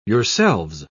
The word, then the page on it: yourselves: British English